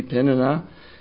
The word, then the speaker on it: Peninnah: I used to say Peninnah. I was trying to get my pronunciations correct, and it seems to be Peninnah.